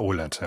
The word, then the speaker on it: OLED: Wird das OLED ausgesprochen? Ich weiß gar nicht. – Ich sag immer OLED, ja.